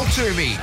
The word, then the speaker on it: Twomey: So to sort out the believable from the bullshlarkin, please welcome Cal Twomey. – Now, are we saying your surname name correctly, by the way? Is it a silent W? – Yes.